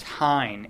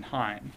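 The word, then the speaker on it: Heien: It was fortuitous that in the meantime, the court decided a case, I think it's pronounced Hein, H-E-I-E-N v. North Carolina, which was just decided middle of last month, I think December 15th.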